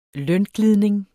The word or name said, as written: lønglidning